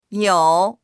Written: you3

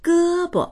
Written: ge1bo

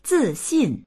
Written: zi4xin4

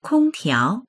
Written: kong1tiao2

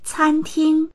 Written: can1ting1

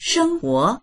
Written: sheng1huo2

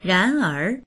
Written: ran2er2

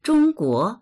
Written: zhong1guo2

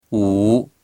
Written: wu3

